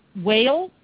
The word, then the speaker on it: Weil: And after that, we get to know Shannon Weil. I'm going to say it wrong. – Wild. – I'm sorry, Shannon. Weil. Thank you.